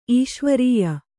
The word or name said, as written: ಈಶ್ವರೀಯ